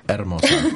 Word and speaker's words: Hermosa: It's Hermosa. – I stand to be corrected. – Yeah, don't forget to roll your R's.